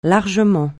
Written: largement

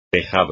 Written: tejado

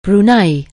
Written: Brunei

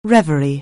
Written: reverie

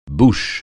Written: bouche

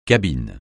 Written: cabine

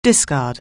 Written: discard

